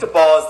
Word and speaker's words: Daboll: Yeah, so Brian Daboll, I think Daboll is the way that he likes it pronounced, but I know some beat reporters that kind of go either way.